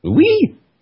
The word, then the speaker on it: Lui: Lui? L-U-I, you pronounce it.